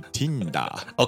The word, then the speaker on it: Tinder: n ンダー。日本語発音でティンダー。